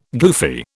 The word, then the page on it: goofy: British English